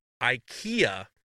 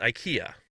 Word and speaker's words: IKEA: But it's not pronounced IKEA, everybody. Ikea. Ladies and gentlemen, it is actually, if you're in Sweden, they say IKEA.